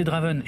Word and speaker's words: Draven: Moi, c'est Draven et sur Twitter, vous pouvez me suivre sur le compte @dravenhardrock. Ça s'écrit D-R-A-V-E-N-A-R-D-R-O-K.